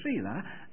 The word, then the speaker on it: Selah: It's spelled S-E-L-A-H. Selah.